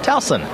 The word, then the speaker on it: Towson: Tucson? – How do you say Towson? – Towson. – Towson? – Towson. I'm sorry, I interrupted.